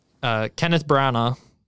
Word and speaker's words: Kenneth Branagh: So Kenneth Branagh, am I saying his name right, I think? – Yeah, you're saying his name right.